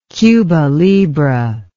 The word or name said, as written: cuba libre